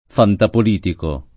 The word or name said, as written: fantapolitico